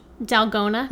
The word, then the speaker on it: Dalgona: Yeah, how did we decide it's pronounced? Dalgona. – Yes, you're right.